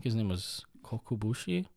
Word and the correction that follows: Kokushibo: Is that right? Kokushibo? Kokubushi. Sorry, I'm mixing up the syllables. Kokushibo,